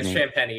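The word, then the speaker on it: Champagnie: him going head to head with Champagny, am I even pronouncing his name right? Is it Champagny or is it Sham? I have no idea. – No, you're pronouncing it right. Champagny. Yep.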